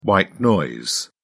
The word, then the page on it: white noise: British English